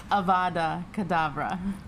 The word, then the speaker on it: Avada Kedavra: There's no H in front of the spell. It's Avada Kedavra.